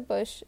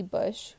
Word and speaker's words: Busch: Kind of think that's how you pronounce his name, but it's spelled B-U-S-C-H. Anyways, on May 7th, 1976, Christopher Bush sexually violated Vincent Gunnels.